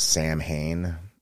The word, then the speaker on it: Samhain: Also, we go to Ireland during Samhain, or Samhain. If you need to see that phonetically spelled in your mind, for Americans, it's Samhain, like the Danzig group, post-Misfits.